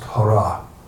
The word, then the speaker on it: Hare: Hare is, Hare by itself is pronounced Hara.